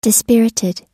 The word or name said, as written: dispirited